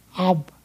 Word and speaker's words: Ab: Abba is an Aramaic word that comes from the Hebrew word Ab, A-B, Ab is where it's pronounced, meaning father.